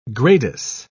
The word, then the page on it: gradus: British English